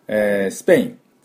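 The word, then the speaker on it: Spain: では、早速いきたいと思いますが、スペイン、スペイン、スペイン、この音なんですね。で、ダブルシーンがポイントです。スプスプスプスプ、このスプスプスプスプと音を出せないと、このスペインって音になりません。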